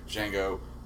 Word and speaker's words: Django: "Django." "Can you spell it?" – D. – Yeah, and he goes, "The D is silent."